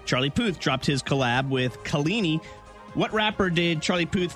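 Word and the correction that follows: Kehlani: You said the girl's name wrong that he collabed with. Can you repeat the question? Sorry. – No, I'm not saying that name wrong again. – I got hung up on that. – Can you please, I beg you, producer Nicole, phonetically write names for me? – I know. I had to ask her earlier because I think it's Kalani, right? – Yep.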